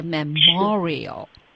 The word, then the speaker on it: memorial: 前面的都是 memory、 memorize、 memorable，这里它的重音呢放在第二个 m 上面了，就是 memorial。